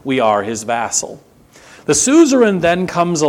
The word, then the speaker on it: suzerain: that great high king would now be called your suzerain or suzerain, if you want to pronounce it the old way, and you would be his vassal.